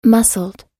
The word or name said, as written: muscled